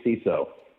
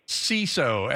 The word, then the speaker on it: CISO: And by the way, do we say CISO or KISO or what do we say? Hard C, soft C? – Typically CISO. – CISO, excellent.